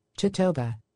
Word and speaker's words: totoaba: Is that right? Am I saying that right? T-O-T-O-A-B-A. It's going to drive me crazy. Let's find out all together. Google search, and then we'll do pronunciation. Pronunciation. There we go. Let's see what Google says how to say this. How to say Totoaboa. – Totoba. – All right, so the Totoba.